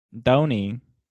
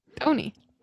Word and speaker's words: Doeny: Oh, I'm Doni? Is that even it's how would you pronounce this? D-O-E-N-Y. – Doni. – Yeah. Doni.